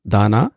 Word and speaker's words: Daana: Give us the correct pronunciation of the restaurant. – It's called Daana.